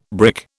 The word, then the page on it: brick: British English